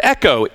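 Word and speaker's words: Echo (ἔχω): It looks like the word echo, E-C-H-O. Echo is how you would pronounce it.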